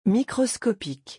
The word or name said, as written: microscopique